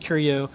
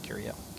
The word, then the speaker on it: Qrio: and Curio was a specific word that I always wanted to use even before Sony. And I think that's how it's pronounced, Curio. Curio. – Q-R-I-O? – Yeah, Q-R-I-O. Okay. Or Q-R-I-O.